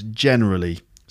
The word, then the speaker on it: generally: So not generally, but generally. So absolute and generally, they're both stressed on the first syllable.